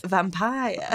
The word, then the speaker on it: Vampyre: And it is also, so Mary Shelley wrote what became Frankenstein, and Byron's doctor, William Polidori, wrote The Vampire, spelled V-A-M-P-Y-R-E. – Can you pronounce it correctly, please? – Vampire. – Thank you.